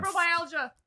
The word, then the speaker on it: fibromyalgia: Fibromyalgia.